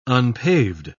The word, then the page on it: unpaved: British English